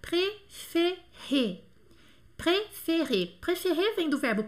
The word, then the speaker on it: préféré: E aqui nós temos três E's e os três têm um som E. Préféré, que significa preferido, né? Préféré, preferido.